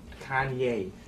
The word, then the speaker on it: Kanye: Kanye. Oh, I'm from Ohio. We say Kenny. That's how we pronounce it. – Well, I don't care where you're from. You pronounce my name Kanye.